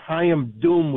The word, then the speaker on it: Chaim Doom: First of all, I'd like to say everyone's pronouncing his name wrong. It's Chaim Doom with D as in dummy.